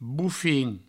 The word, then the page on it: bofin: Provençal; boufin